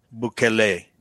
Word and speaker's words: Bukele: Okay, so we're going to talk about the president of El Salvador, Nayib Bukele. Is that how it's pronounced? – Bukele, yeah.